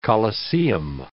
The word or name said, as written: coliseum